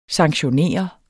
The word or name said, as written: sanktionere